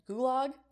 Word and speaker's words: gulag: Polish soldiers / prisoners of war who had just been released from a gulag in Siberia. Did I say that right? Is that gulag? – Yeah, that was right.